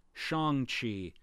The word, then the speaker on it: Shang-Chi: The following is the proper pronunciation. It's Shang-Chi, Simu Liu, Dad.